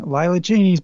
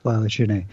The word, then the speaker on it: Lila Cheney: Lila Cheney's back in town. And I'm sorry, you pronounced it wrong. – Oh, sorry. Lila Cheney.